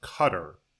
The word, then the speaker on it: Qatar: Do you know how to say Q-A-T-A-R? The country. – I've heard it a couple of different ways. – And I said Qatar, and I'm wondering if Kenny said it was Qatar, but that sounds fake. – I assumed it was Qatar and had heard it that way. And then at some point, I think I heard it's actually pronounced Qatar. – Qatar, that's what it is.